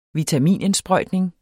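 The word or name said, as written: vitaminindsprøjtning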